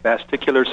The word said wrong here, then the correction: vestibular: syndrome. – Okay. Very close. It's vestibular. – Vestibular, okay. Yes.